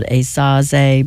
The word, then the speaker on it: Esaze: the CD is called Esaze, but it's spelled E-S-A-Z-E.